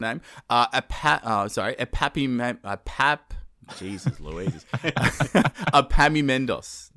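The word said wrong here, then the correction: Epaminondas: No? Apaminondas. There we go. Apaminondas.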